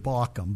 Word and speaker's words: Baucham: I think he pronounces it Bauckham, but either way, B-A-U-C-H-A-M.